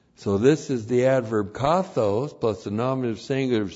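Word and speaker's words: kathōs: The adverb kathos looks like this in the Greek, K-A-T-H, long O, S, kathos.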